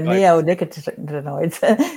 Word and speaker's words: neonicotinoids: So let's go on to, and I know I would butcher this, neon icotobids. – Yeah, neonicotinoids. You missed a syllable there.